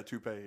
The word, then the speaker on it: toupee: Dude, he has a toupee. Was that how you pronounce it? Like a wig closet. – Yeah, toupee. Yeah.